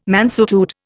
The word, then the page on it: mansuetude: Indian